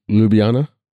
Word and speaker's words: Ljubljana: Ljubljana? – Ljubljana, yeah. – There you go. Gotcha. – Yeah, yeah, the J's. You don't pronounce the J's.